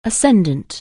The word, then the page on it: ascendant: anglais